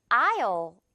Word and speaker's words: aisle: 자, 이것도 S는 유령 친구예요. 이 철자로는 존재하지만 아예 없는 소리에서는 전혀 존재하지 않는 유령 Ghost입니다. 그래서 어떻게 발음되냐면 아요 이렇게 발음이 돼요. 아요. 스펠링상 이 S가 아예 없다고 생각을 하면 오히려 발음이 편할 거예요.